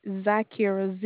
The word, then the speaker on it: Zykira: Zakira. – Kira, K-I. How do you spell that? – Is Zakira, Z-Y-K-I-R-A.